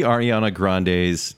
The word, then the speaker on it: Ariana Grande: And do we see Ariana Grande's...